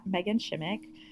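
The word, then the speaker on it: Meghan Shimek: Well, my Instagram is probably the best or most up-to-date usually place to find me, and my Instagram is at Megan Shimmick.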